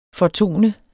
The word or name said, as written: fortone